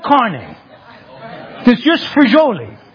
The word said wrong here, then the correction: frijoles: Frijoli, right? What's frijoli? Wait, quiet. Frijoles. Okay.